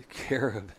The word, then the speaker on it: carob: There's a mesquite, a pistache, Aleppo pine, cork oak, pecan, date palm, cottonwood, olive, Montezuma cypress, and what's a carbo? – It's a carob. – Carob. C-A-R-B-O.